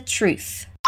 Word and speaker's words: truth: A nossa última palavrinha ali termina com TH. Lembra como a gente trabalha a pronúncia do TH? Ali no final é a pronúncia do TH não vozeado. Repeat: truth.